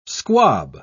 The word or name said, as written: squab